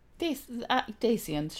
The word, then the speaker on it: Dacians: Wait, is it Dacians? How do you pronounce it? – Dacians, sure, fine.